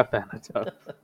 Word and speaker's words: Thanatos: Someone named Thanatos? Is that how you pronounce it? Thanatos? – Yeah, Thanatos.